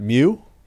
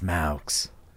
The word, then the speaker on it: Meux: M-E-U-X. – Maux. – Maux. What's the pronunciation on that? Mew? – It's pronounced Maux.